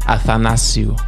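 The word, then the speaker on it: Athanasiou: They got Andreas Anastasiou or something. I forget. I don't know how to pronounce his name, man. – Come on, bruv. It's your shit on point, man. It's Andreas Athanasiu.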